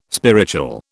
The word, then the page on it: spiritual: British English